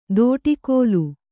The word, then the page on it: ದೋಟಿಕೋಲು: dōṭikōlu